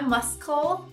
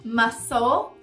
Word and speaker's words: muscle: O C mudo a gente encontra na palavra músculo, muscle, não é muscle, muscle, tesoura, scissors, scissors.